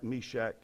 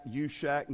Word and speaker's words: Meshach: And I heard one preacher who was not as educated as some of the others mispronounce their names. Meshach, Yushach, and to bed we go. That's what he said.